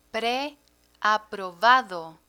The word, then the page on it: pre aprovado: Spanish